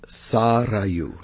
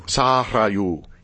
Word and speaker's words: Sarayu: Sarayu? – It's actually pronounced Sarayu. – Sarayu. – Yeah, the R is rolled.